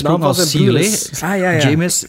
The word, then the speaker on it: Jeymes: Eh, ja, James is het uitgesproken, denk ik. Samuel. James is dus J-E-Y-M-E-S.